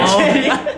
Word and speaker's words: Choerry: é Thierry. Thierry, o nome dela pronuncia assim, mas escreve Choerry.